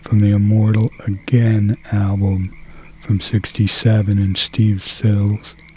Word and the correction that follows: Stills: Steve Stills.